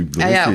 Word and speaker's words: Hublot: Und zwar von der Luxus, absoluten Luxusuhrenmarke Hublot. – Also die alle Hublot aussprechen? – Ja, genau.